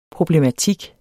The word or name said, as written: problematik